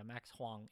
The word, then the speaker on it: Huang: And, yeah, Max Huang. H-U-A-N-G. – How do you spell that? H-U-O-N-G? – H-U-A-N-G. – Huang, okay.